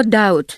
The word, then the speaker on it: doubt: Но вот смотрите, возьмем слово doubt, да? Почему англичане пишут эту букву B, но не произносят?